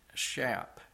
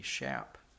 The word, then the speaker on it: Schaap: Jack, I think it's pronounced Schaap, S-C-H-A-A-P, Schaap. Schaap.